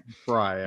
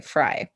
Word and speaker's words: Frey: Frey, actually, but it looks is like. Is it Frey? Yeah. It just seems like Frey in my mind. Well, it's spelled like that too. But okay, Frey.